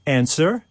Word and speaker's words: answer: Or answer. – W.